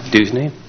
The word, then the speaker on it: Duszny: Deesney. – Deesney? – Yes. – Can you spell that, please? – D-U-S-Z-N-Y.